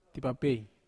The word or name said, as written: tapapei